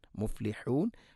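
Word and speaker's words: മുഫ്ലിഹൂൻ (مفلحون): ഹിസ്ബ, ഹിസ്ബല്ലാഹി ഹുമുൽ മുഫ്ലിഹൂൻ, മുഫ്ലിഹൂൻ ഹാ ഉച്ചാരണം ശ്രദ്ധിക്കുക, തൊണ്ടയുടെ മധ്യഭാഗം.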